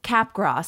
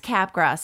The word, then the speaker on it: Capgras: Well, it's named after a man named Joseph Capgras, and he was French. So I think that you drop the S sound.